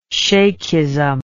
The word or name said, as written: shaikhism